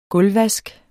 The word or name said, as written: gulvvask